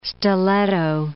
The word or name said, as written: stiletto